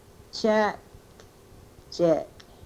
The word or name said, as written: chekchek